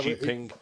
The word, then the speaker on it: Xi Jinping: Is it Xi Jinping? – Jinping, yeah. – Xi Jinping.